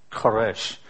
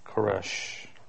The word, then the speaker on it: Koresh: It's Koresh. Remember there was used to be a branch Davidian guy named David Koresh? That's Cyrus, right? Koresh. Persian pronunciation now.